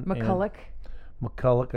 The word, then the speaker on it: McCullough: And so McCullough. – Yeah, McCullough has one. – McCullough. – I actually said it right after 2 years.